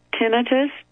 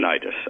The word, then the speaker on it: tinnitus: tinnitus, How do we pronounce that when you're dizzy? You know, you hear that noise. – Tinnitus, I think. – Tinnitus, I think that's right.